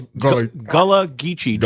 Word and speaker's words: Gullah Geechee: I believe it's Gullah Geechee. G-U-L-L-A-H. It had to do with a certain African colony in the West Indies that ended up with people in South Carolina.